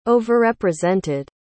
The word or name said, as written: overrepresented